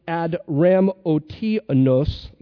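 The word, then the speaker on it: Adramyttium: Verse 2 says, and embarking on Adramotinus, that's how it's pronounced.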